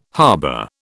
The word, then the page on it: harbour: British English